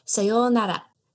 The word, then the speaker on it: sayonara: Yes, with a long O in the middle because it has an O-U. So, sayonara. However, this is very, very formal, even though you may have heard it in American movies as sayonara. not pronounced right.